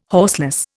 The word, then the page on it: horseless: Indian